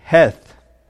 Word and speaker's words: Heth: It's actually pronounced heth, not teth, but heth.